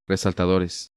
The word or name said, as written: Resaltadores